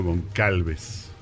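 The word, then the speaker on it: Gonçalves: Paulo Goncalves. Goncalves, eh, González, eh, lo, pronunciarían por allá de este lado para que lo puedan ubicar.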